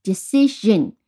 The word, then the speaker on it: decision: 那么它要变成了名词，就是 decision。好，注意这个读音 shin，你联想到什么？television 对吧？是和 television 这个 shin 读音是一样的。